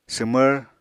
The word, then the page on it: swmvr: apatani